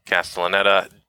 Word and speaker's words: Castellaneta: And I will also say that apparently Dan Castellaneta, I can never say his name right. – Castellaneta did actually approach Robin Williams to ask if it was okay to do.